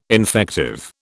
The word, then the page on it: infective: British English